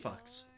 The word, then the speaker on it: Fuch: Well, some people actually have the last name F-U-C-H. – And they're like... – It's pronounced fucks.